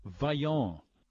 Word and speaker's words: Vyoone's: It's called Vyoone's, uh, and it's spelled V Y O O N E apostrophe S.